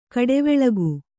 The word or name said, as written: ಘಟ್ಟಿವಾಳಿಕೆ